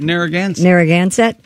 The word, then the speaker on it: Narragansett: The Narragansett, how do you say this? Narragansett Police Department. – Narragansett.